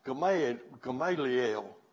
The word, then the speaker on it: Gamaliel: And he studied under one of the most influential rabbis in the Jewish religion at that time, Gamaliel would be the Jewish pronunciation.